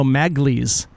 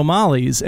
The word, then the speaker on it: Magli: In season one, Frasier said Bruno Magli's. He mispronounced Bruno Mali's, and in this episode, he says it correctly.